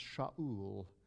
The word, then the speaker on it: Shaul: Saul, whose name is pronounced Shaul, is now in Sheol.